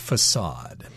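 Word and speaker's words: façade: Notice that the word facade, in this particular case, I don't think it has that diacritical mark, but it's supposed to have a little cedilla at the bottom, and that means that the letter C above it should be pronounced with the S sound, facade.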